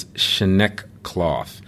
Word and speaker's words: Schnekloth: And so, Clint, and Clint's last name is Schnekloth, and it sounds like it's spelled.